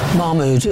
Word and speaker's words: Mahmoud: Mahmoud, is that right? Have I said that right? – That's good enough, thank you.